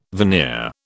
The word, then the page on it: veneer: British English